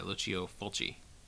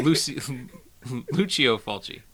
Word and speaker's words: Fulci: This episode we are talking about the 1981 horror film The Beyond by Lucio Fulci. Did I pronounce that right? No. Lucio Fulci. – Yeah, there you go.